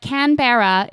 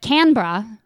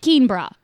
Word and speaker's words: Canberra: What I've been told is that Canberra is the correct formal pronunciation, but Canberra, some softening the R, is pretty universally accepted. Universally accepted alternative for people with stronger accents, but evidently nobody calls it Keenbra.